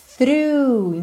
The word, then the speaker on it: through: Então, olha atenção à pronúncia dessa palavra que se escreve T-H-R-O-U-G-H. A pronúncia é mais fácil do que a escrita, né? Through. Lembra de pôr a linguinha lá na ponta dos dentes da frente, th, th, th, e through, through, ok?